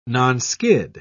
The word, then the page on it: nonskid: British English